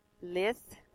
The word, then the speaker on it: lithe: Lith is how you say, L-I-T-H-E, supply, life.